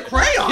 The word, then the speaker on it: crayons: Wait, did you say crayons? – Yes, it's crayons. – It's one of those words, it's one and a half syllables.